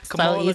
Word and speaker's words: Kamala: First of all, you ain't even saying her name right. Kamala. – Kamala.